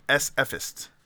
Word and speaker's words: SFist: Now it's SFist. – That's SFist. Their crime blotter. – I think it's pronounced fist. – S-fist. – S-fist.